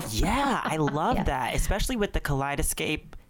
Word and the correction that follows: kaleidoscope: Did I say that right? Kaleidoscape. – Kaleidoscope. – Kaleidoscope.